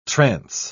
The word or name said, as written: trance